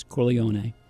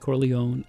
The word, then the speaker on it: Corleone: The Italian pronunciation is Corleone, but the American is Corleone, and in every movie, every conversation, people use both of them.